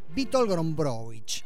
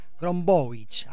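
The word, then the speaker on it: Witold Gombrowicz: Y eso que le pregunté a Nergal, polaco él, y cantante y frontman de Behemoth, cuando estuvo aquí frente a estos mismos micrófonos, cómo se pronunciaba. Bueno, trato de reproducirlo. Witold Grombowicz. Grombowicz, ahí está.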